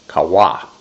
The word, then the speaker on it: qawah: It's spelled Q-A-W-A-H, kawa.